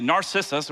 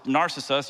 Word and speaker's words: Narcissus: And so Narc- Narcissus, I have a hard time pronouncing it, Narcissus or Narcissus, you say tomato, I'll say tomato, okay?